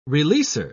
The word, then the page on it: releaser: British English